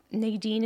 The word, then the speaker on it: Nadine: That Nadine is spelled N-A-D-I-N-E.